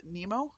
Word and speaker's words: Nymo: So I ordered the beading thread, which is spelled NYMO, but I think Allie Aller, because she was referring to it on Craftsy, calls it Nemo, I think is how it's pronounced.